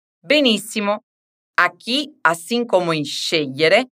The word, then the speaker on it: scegliere: Diciamo tutto e fai attenzione alla tonica. Scegliere. Scegliere. Bravissimi!